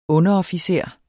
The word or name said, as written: UNDEROFFICEREN